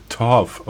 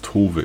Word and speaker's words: Tove: Und deswegen habe ich es nicht Torf ausgesprochen, sondern ich gehe davon aus, dass es Tove ist.